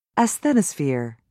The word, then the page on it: asthenosphere: American English